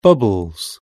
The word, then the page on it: bubbles: British English